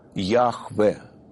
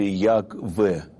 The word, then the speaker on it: Яхве: Мы произносим его Яхве или Ягве, Г фрикативное, Г, вот, Яхве.